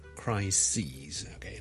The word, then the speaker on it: crises: 一个危机啦，a big crisis 啦，但系 number of crises，OK，、 okay? 留意个发音啊，串字当然系唔同啦，个发音系唔同嘅，crisis 同 crises。